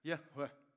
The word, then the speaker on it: Yahweh: It's actually pronounced at best, Yahweh.